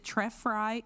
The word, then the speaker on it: Trefry: They pronounced her name for us. We were like, is it Trefry? Is it Trefry? It's Trefry.